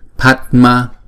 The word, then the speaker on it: Padma: Chữ A này nè, chữ A màu đen mà không có dấu gạch ngang trên đầu đó, chúng ta đọc là A, A, A, không? Thì chúng ta ráp lại, chúng ta phải đọc là Padma, Padma.